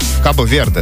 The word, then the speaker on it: Кабо-Верде: Как правильно назвал? Кабо-Верде, да? – Да.